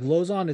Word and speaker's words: Lauzon: Literally, I'm pretty sure his nickname is J-Lo, because the low and Lauzon is pronounced. – Yeah, like I guess Cerrone, I suppose. – I think Lozon's gonna beat the dog shit out of him, dude.